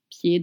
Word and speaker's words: pied: Do you notice you don't pronounce the D at the end of pied?